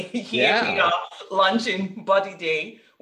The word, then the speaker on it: Bodidey: B-O-D-I-D-E-Y, Body Day.